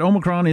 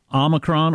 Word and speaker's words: Omicron: And it says here that Omicron is pronounced, well, it's got two pronunciations, so I guess that means you can do it either way. Omicron or Omicron. So you can do either one.